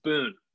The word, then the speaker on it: Boone: It's a pretty Southern last name. It's Boone. – Boone. But it's B-O-O-N-E.